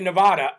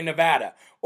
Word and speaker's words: Nevada: Somebody actually corrected me on how to say Nevada, too. I shouldn't say Nevada. I should say Nevada.